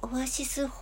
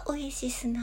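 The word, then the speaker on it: Oasis: オアシスホルダー。これは、えー、えー、オエシスですね。発音はオエシスなんですけれども、地、オアシス、ホルダーですね。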